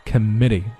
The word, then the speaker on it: committee: It begins by referring the matter to a, 连起来 to a committee, committee, 这个字读得很快啊 ,k, 注意不是 come 哈，在后面啊，没有在前面发音，前面是弱读。 Committee 这是重读的音节，